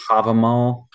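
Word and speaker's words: Hávamál: Oh, Havamal.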